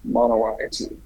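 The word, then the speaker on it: Monowi: Manawa, it's way up in the northeast corner of the state. – How do you spell it? – It's M-O-N-O-W-I. – Manawai.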